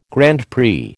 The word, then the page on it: Grand Prix: American English